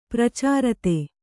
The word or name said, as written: ಪ್ರಚಾರತೆ